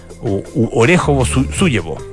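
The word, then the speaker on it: Orekhovo-Zuyevo: Orejovo-Sujevo. Orejovo-Sujevo. Así es la pronunciación correcta.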